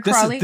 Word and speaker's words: Crawley: Clare Crawley is that her name? Am I saying that right? Yep, that's right. Clare Crawley.